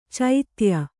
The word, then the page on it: ಚೋದ್ಯ: Kannada